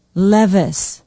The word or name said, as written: Levis